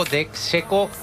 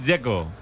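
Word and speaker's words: Džeko: A mí el que me parece difícil es el de Checo, Dzeko. Porque DZ, ¿cómo se pronuncia? – Dzeko. Bruno dice que es Dzeko. – Como tirando a J, Dzeko o algo así.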